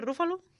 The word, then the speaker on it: Ruffalo: ¿Se pronuncia Ruffalo? – Sí, Ruffalo, creo.